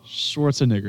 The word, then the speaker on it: Schwarzenegger: Schwarzenegger, something like that. Hey, I was close. – Schwarzenegger.